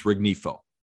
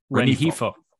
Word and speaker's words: Rengifo: Leading off was Luis Rengifo. Did I pronounce Rengifo that correctly? – Rengifo.